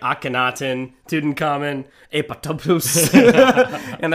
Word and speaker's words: apoptosis: The second P in apoptosis is silent.